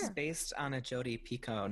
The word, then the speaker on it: Picoult: So did I say her last name right? – You did. Congratulations. – Like Pico de Gallo. – Yes, exactly. But spelled with an L and a T. O-U-L. – Picoult. – Uh-huh. Most people say Jodi Picoult, but it's not.